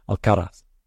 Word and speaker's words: Alcaraz: People say, he was asked, well, how do you like it to be pronounced? And he said, Alcaraz. So you've got to do that today now. Otherwise, you sound like a fool.